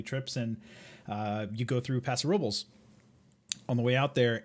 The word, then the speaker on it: Paso Robles: According to the can, it's in Paso Robles.